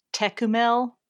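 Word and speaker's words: Tékumel: Well, I've seen it with an accent mark over the first E, which makes me think that it should be Tecumel. The emphasis on the first syllable, but again, I am also, maybe I should have actually looked more at the appendix talking about pronunciations.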